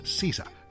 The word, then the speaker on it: SZA: El nombre artístico de Solana y Manny Rose escribe SZA, pero se pronuncia SZA.